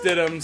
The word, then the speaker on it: Stidham: Pronounced Stidham, spelled Stidham. Ari.